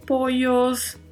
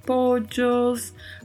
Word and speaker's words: pollos: Pojos, que tem aí um duplo L né, na pronúncia, e este duplo L, como já mencionamos em outros podcasts, pode ter variação a depender da localidade da sua pronúncia, né? Pode ser poios, pode ser pojos, pochos, né? vai depender muito da localidade e I, que é uma conjunção copulativa.